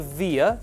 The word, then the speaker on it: VIA: It's called the VIA, V-I-A or VIA, depending on the way you want to pronounce it.